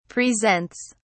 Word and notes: presents: British English

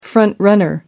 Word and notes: front-runner: American English

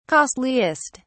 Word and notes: costliest: British English

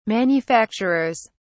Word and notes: manufacturers: British English

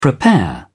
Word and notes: prepare: British English

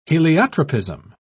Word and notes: heliotropism: British English

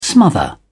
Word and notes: smother: British English

